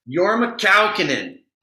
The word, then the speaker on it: Jorma Kaukonen: Jorma Kaukonen.